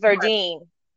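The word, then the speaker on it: Verdine: I say it wrong every time. It's Verdeen.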